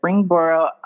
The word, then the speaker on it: Springboro: Taylor, 32, loan officer, Springboro, Ohio.